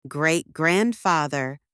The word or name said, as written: great-grandfather